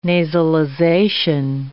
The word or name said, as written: nasalization